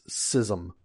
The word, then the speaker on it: schism: And he insisted that the right way to say it is schism.